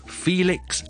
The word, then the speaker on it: Felix: Felix and Phyllis，第二个唔同嘅地方咧就系咧 Felix F E L I X 咧。第一個 syllable 是 E 音嚟嘅，所以咧我係緊少少嘅。